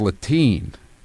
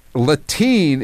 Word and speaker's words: Latine: No, it's Latine. Rhymes with, what does it rhyme with? It rhymes with latrine. – Canteen. Pantene. – Latine is a term, quote, created by gender non-binary and feminist communities in Spanish-speaking countries.